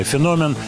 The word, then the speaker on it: феномен: Например, такие, как вы объясните феномен, ударение правильное, феномен Sex Pistols?